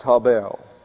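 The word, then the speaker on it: hebel: Yes, it's H-E-B-E-L, but pronounced habel.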